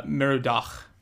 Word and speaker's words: Merodach: Merodach.